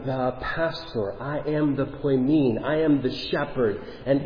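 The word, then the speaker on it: poimen (ποιμήν): The Greek word, here, if you get a little Greek lesson this morning, poimen. Poimen. Maybe I'm not pronouncing that right, but I say I am. So, poimen. There you go. Unilaterally. This is how it is supposed to be pronounced. Poimen.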